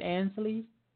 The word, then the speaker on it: Ainsley: Now I mentioned um, Ansley it, am I pronouncing that right, Ainsley? – Yeah, Ainsley Taylor.